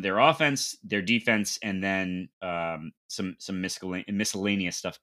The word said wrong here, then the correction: miscellaneous: I pronounced the C in miscellaneous.